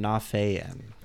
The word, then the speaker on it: Nafian: I tend to emphasize the A a little bit. Nafian.